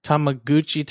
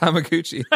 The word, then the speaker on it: Tamagotchi: Oh, Tamagotchi. – Tamagotchi. All right. Do you guys remember Tamagotchis? So let's say there was a I think it's pronounced Tamaguchi.